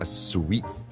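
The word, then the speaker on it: suite: a sweet.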